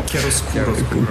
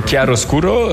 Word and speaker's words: Chiaroscuro: Il y a un album qui est sorti il y a un an maintenant qui s'appelle Chiaroscuro, je prononce bien correctement? – Chiaroscuro. – Chiaroscuro, qu'on encourage en fait à écouter quasi dans le noir.